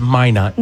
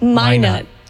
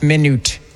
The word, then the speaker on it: Minot: Because I was just waiting to hear one pronunciation. It's Minot. – Minot.